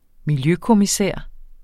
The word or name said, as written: miljøkommissær